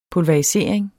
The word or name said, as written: pulverisering